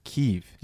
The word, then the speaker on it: Kyiv: Oh, I didn't get the update. Kyiv. – Yes. – Sorry. – Yeah, you better believe they spell it the right way. – Kyiv. – K-Y-I-V.